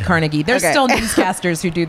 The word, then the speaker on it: Carnegie: They're in Carnegie.